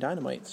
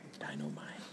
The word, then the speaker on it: Dynamite: I didn't go to the Dynamite table, because I don't normally read anything Dynamite, so. – You're saying it wrong. It's Dynamite.